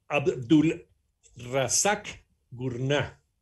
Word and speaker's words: Abdulrazak Gurnah: Eh, Abdul Razak Gurnah. – Exacto. lo dije bien? – Lo perfecto.